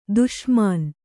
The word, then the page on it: ದುಸ್ಮಾನ: dusmāna